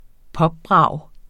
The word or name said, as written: popbrag